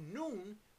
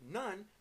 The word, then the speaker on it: Nun: the son of noon. We pronounced it nun, but it's noon, the son of noon.